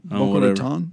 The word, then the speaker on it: Boca Raton: The latest war on Christmas dust-up comes from us from Boca Raton, Florida. Is that, how do I say that? – No, Boca Raton.